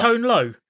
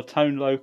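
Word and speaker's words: Tone Lōc: Is it not pronounced rapper. – Tone Low? – You're thinking about the rapper, aren't you? Or Tone Low.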